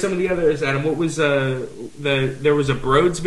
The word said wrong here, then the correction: Broods: video this week that was super cool. – Isn't it Broods? – I think so. – That's right, Broods. Laugh, L-A-F.